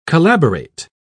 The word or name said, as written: collaborate